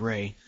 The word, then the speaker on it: Rei: Ren? You mean Ray? – Is that how you say the name? – R E I. It's, so, Ray. Yeah. It's pronounced Ray.